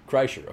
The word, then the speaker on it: Kreischer: How do you say his name? – Kreischer. – Okay, Bert Kreischer.